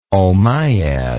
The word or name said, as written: omayyad